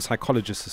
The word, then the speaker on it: psychologist: And then I found out later that the P in psychologist is silent.